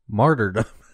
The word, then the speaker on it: martyrdom: And you would call it Marty. – Yeah. Well, I would always. Dude, this is me finding out it's pronounced that way. Actually. – Well, there is an R in there. Martyrdom.